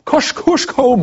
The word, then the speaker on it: Kosciuszko: Kosciuszko Mound.